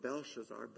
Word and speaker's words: Belshazzar: but now Belteshazzar does not respond. Nebuchadnezzar did. Belteshazzar does not. I said that wrong. Belshazzar. Belteshazzar is Daniel's name in Aramaic. Belshazzar, so similar, isn't it? Is the king's name.